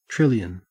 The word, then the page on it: trillion: Australian English